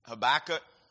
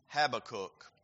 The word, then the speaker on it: Habakkuk: Some pronounce it Habakkuk. That's how I pronounce it. Some Habakkuk.